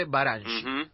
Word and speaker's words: Baranyi: Mi nombre, eh, ustedes se pronuncian Baranshi. Baranshi. – Imre Baranchi.